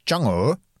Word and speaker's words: Chang'e: Og den heter jo da, vi skriver det Chang'e 5, men jeg så en kineser si, nå må dere skjønne, dere får prøve å uttale det riktig, og det Chang'e. Det sånn de sier det,